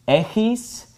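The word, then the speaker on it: echis (ἔχεις): This word is pronounced echis, and this is pronounced echete. All right, so echete and echis are the second person, you, singular and plural.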